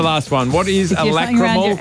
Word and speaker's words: lacrimal: Lacrimical carungles. – That is not how you say it, I'm sure. – Carunkle. – What? – A lacrimal carunkle.